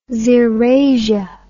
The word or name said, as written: xerasia